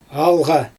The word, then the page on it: alGa: Xyzyl